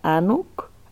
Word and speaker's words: Anuk: Yo decidí, bueno, me llamo Mariana Guerrero, el apellido lo he dejado, decidí tener de nombre de batalla Anouk, pero varios dicen Anouk. Yo le puse el acento en la U, o sea, hice como una intervención al mismo nombre.